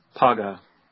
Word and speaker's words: Paga: Lalila becomes the hero Hadon's love interest, and then there's the manling Paga, who is actually called Pog in the Haggard novels, but according to the way that Co-Carsons speak, they couldn't end a syllable with a G, with a hard G sound, so they had to say Paga.